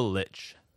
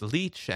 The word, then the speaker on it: Lich: There's the Lich, which I've also heard pronounced leech and lick and all sorts of other things, but I think Lich is the agreed upon pronunciation. – Yeah, I think it's Lich.